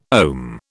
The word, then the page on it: ohm: British English